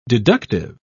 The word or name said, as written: deductive